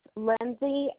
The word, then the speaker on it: Lindsay: Lindsay, L-I-N-D-S-A-Y, McDonald, M-C-D-O-N-A-L-D.